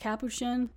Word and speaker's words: capuchin: He has a friend who works at this lab who's like, hey, I got this, I want to say it's a capuchin, but it could also be pronounced capuchin monkey.